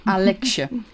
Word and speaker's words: Aleksej: Alexje. Ik hoop dat ik het goed uitspreek. Het is in ieder geval A-L-E-K-S-E-J. Maar in ieder geval... Ja, volgens mij spreek je het goed uit.